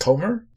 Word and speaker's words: Comer: And next up we have Kate, I believe the last name is pronounced Comer.